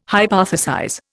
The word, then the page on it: hypothesize: Indian